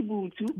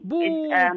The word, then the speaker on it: Vhuthu: It's spelled V-H-U-T-H-U culture. How do we pronounce it? – It's actually Bootu. Bootu. – It's in Tshivenda.